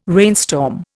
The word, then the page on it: rainstorm: Indian